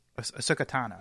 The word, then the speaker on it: Ahsoka Tano: Okasawa. – Ahsoka Tano.